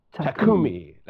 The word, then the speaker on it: Takumi: And what I particularly like about Takanomi, is that, am I pronouncing that correctly? – Takumi. – Let's go. Yeah, Takumi.